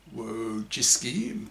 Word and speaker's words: Wojcicki: The CEO of YouTube, Susan Wojcicki, pronounced Wo as in woe is me and Jisky as in frisky, who created this policy, is the most hated woman on the internet by the Manosphere.